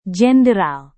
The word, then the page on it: jenderal: Indonesian